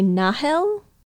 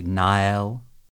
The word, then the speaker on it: Nahel: So the new ship, they spell it in English, N-A-H-E-L, space, Argama. – Which, if I were pronouncing it with no other information, I would probably say Nahel? – Kind of like the Sahel Desert? – Yeah.